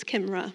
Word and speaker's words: Kimbrah: If we haven't had the pleasure of meeting before, my name is Kimbrah, it's been said a few times. I feel the opportunity to actually tell you how my name is actually pronounced, because there's a silent B in it. It's Kimbrah.